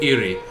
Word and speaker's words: Irri: Is it Erie? – Yeah, like the lake. – Erie, like the lake, yeah. Do you want to, how is it spelled? – I-R-R-I. – I-R-R-I.